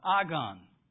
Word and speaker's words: agon: The word fight is the word agon. Agon, okay? Phonetically, it'd be spelled A-G-O-N.